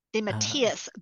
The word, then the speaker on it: DeMatteis: De Matthias. Good. I'm going to say it like that from now on because I always say De Matthias. De Yep. And he corrected me.